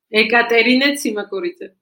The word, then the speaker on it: Ekaterine Tsimakuridze: Ekaterine Tsimakuridze.